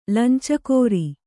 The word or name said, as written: ಲಂಚಗಾಱ